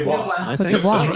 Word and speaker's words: Côte d'Ivoire: Is that how you say it? – Côte d'Ivoire. Very good.